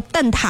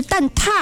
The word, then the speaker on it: 蛋挞: 是的，然后其实咱们口语话都叫蛋挞哈，理论上这个读标准读音是蛋挞啊，给大家做一个小小的知识的普及。